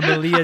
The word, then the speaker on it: melee: No, melee. I always said melee. I always find it weird. – Yeah, I think melee is the right way to say it. But I used to always say melee, and then someone corrected me, and I was like, well, you say turret.